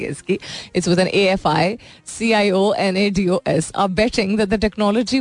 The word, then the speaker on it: aficionados: मैं इसकी pronunciation सही तरह देखती हूँ. Aficionados. सही तो कह रही हूँ मैं.